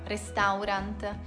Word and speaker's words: restaurant: Quante volte ho sentito la parola restaurant, restaurant? In realtà questa parola si pronuncia esattamente così. Restaurant. Rest. Rent. Provate a suddividere la parola in due parti. Rest, rent, restaurant.